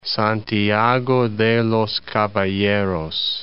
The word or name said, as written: Santiago de los Caballeros